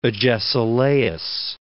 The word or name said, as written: Agesilaus II